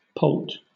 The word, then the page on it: poult: British English